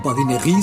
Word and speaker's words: badinerie: Badinerie se escribe badinerie y solemos pronunciar en castellanizado badinerie en español.